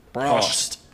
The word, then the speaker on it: Prost: Prost? – Prost. – Prost. – But with like a German accent. – Prost.